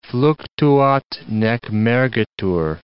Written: fluctuat nec mergitur

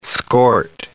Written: skort

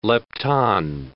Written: lepton

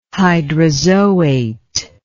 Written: hydrazoate

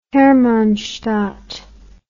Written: Hermannstadt

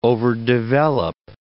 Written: overdevelop

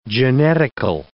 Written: genetical

